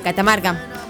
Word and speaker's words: Catamarca: es de Cacamarca. Catamarca. Catamarca, nuestro querido amiga.